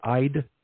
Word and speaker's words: Eid: I just had, anyway, I just had the meaning, the first one, the first word EID, it shows a long I, so it like I'd, yeah, I was trying to get the pronunciation of it online.